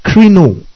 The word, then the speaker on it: krino: Now that word judge here, it translates the Greek krino. K-R-I-N-O. The last O accentuated. Krino.